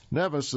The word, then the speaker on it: Nevis: Well, Nevis is spelled N-E-V-I-S. There's also a Caribbean island spelled the same way, but pronounced Nevis.